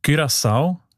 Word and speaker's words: Curaçao: Das Land, habe ich gelernt, spricht man eben mit «ü» aus, wegen, weil Holländisch dort auch eine wichtige Sprache ist. Und im Holländischen ist das «u» offenbar häufig als «ü», Curaçao.